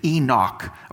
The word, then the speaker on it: Enoch: In Hebrew, it's k at the end. It's Enoch. We're going to get that hard C on the end.